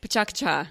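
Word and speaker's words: Pecha Kucha: I don't think that's how you say it. – Yes. Yeah. Yeah. There's been much debate. Much debate. – Pachaka Cha. – Pachaka Cha.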